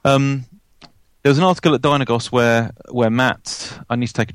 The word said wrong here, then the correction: Mart: It's Mart. – Mart.